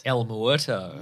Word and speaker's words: El Muerto: El Muerto.